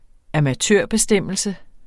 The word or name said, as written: amatørbestemmelse